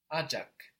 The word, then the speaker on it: acak: Ajak. Good. This is spelled E-C-E-K. Or A-C-A-K. So we see that the C in Turkish makes a J sound, J.